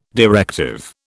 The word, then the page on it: directive: British English